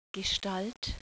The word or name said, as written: Gestalt